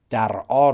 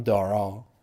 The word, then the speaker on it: درعا: مثلا شهر درعا رو من گفتم دارا یعنی همون همونطوری که تو انگلیسی ها میگن گفتم و یه شهر مهم دیگه شهر حلبه که تو انگلیسیش میشه لپو.